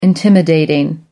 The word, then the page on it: intimidating: American English